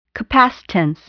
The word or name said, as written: capacitance